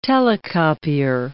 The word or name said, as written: Telecopier